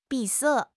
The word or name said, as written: 闭塞